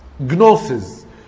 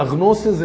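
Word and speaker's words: جنوسيس (γνῶσις): وخلي بالك المعرفة أو المصطلح اليوناني المستخدم هنا من أصل كلمة يونانية جنوسيس. جنوسيس دي اللي جت منها كلمة غنوصية. الجمة دي ساعات بتتنطق غين فتبقى غنوسيس اللي هي الغنوصية.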